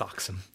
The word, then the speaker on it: dachshund: The spelling is there, but it is pronounced dachshund.